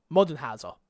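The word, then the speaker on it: Moldenhauer: Modenhauser is how you say that.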